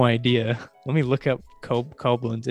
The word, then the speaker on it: Koblenz: Oh, it says I looked up pronunciation. It says Koblenz. Yeah, it says Cowblins.